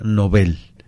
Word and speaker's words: Nobel: Lo cierto es que es con N mayúscula, con B, y que se pronuncia Nobel. Uh-huh. ¿Por qué? Porque se respeta la pronunciación que viene del sueco de origen, y de hecho, si lo pensamos, Nobel es una palabra aguda que termina en una consonante y por lo tanto no debe ir acentuada, como correctamente lo es.